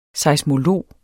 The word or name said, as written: seismolog